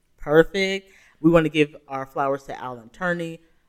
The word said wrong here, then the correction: Turing: Harvey. – Turing. – Sorry, I'm saying his name wrong.